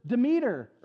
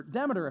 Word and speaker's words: Demeter: We worship Demeter, or Demeter, if you prefer that pronunciation.